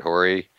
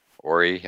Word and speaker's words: Horry: Robert Horry. Horry, I think it is pronounced. – Horry, right. Yeah, Silent H. Horry, yeah.